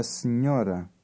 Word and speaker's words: senhora: The combination NH is pronounced N. – Try word 10. – A senhora.